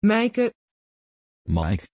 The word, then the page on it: Maik: Dutch